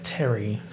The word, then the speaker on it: Thierry: And then next to Kim Kardashian is, I think, I don't know how to pronounce this name, T-H-I-E-R-R-Y. It's like Terry, but not Mr. Mugler.